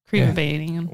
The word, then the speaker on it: Crevabanians: The Cribbabanians, Crevabanian. – Yeah. What'd you say? – It's more of a creve sound at the beginning.